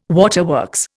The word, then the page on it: waterworks: Indian